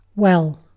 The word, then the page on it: well: British English